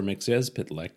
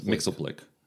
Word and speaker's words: McYezpitlick: I know it says it right here. Pronounced McYezpitlick. – Yes. – McYezpitlick. – McYezpitlick. – Now, of course, I grew up watching the Super Friends cartoons. – Well, they'd do it all wrong. – Yeah, they call him Mr. Mixelblick. – That's wrong. – Yeah. – They should have read this text box, which teaches you how to pronounce it.